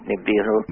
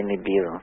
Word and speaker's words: Nibiru: I pronounce it Nibiru. – Nibiru? – No way of asking the Sumerians how they pronounced it, but I would say Nibiru.